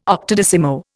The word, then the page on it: octodecimo: Indian